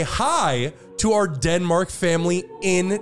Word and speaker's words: Hej: It's spelled H-E-J, but it is pronounced the same way as hi here in English.